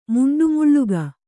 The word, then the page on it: ಮುಂಡುಮುಳ್ಳುಗ: muṇḍu muḷḷuga